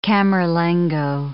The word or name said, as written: camerlengo